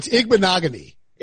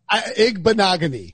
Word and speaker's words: Igbinoghi: It's Igbinoghi. – Igbinoghi? – Igbinoghi. – Like rhymes with monogamy.